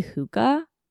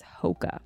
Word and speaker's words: Hoka: I kept wanting to say Hoka, but everyone keeps telling me that it's Hoka.